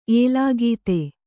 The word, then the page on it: ಏಲಾಗೀತೆ: Kannada